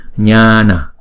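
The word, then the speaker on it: jñāna: Nha nè, Nha nè. Chữ này, chữ chữ J này là chữ J câm, không đọc. Đọc chữ chữ N có dấu ngã là chúng ta đọc là nhờ.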